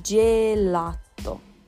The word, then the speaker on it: gelato: Gente, o gelato italiano, e a gente pronuncia assim, gelato, tá? Porque o G com E faz G em italiano, como vocês já aprenderam comigo.